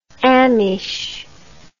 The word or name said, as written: ameche